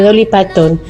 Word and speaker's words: Dolly Parton: de Dolly Parton.